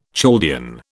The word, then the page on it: Chaldean: British English